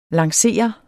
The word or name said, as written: lancere